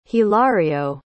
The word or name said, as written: hilario